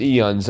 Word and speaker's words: aeons: It's pronounced eons. I think they just... The A is dropped, but... – Silent A. – It's a silent A that they just sort of...